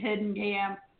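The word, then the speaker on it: Heitkamp: Heidi Hittenkamp.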